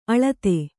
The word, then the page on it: ಅಲತೆ: Kannada